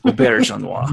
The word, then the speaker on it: Auberjonois: Auberjonois. – And that's how to pronounce his name.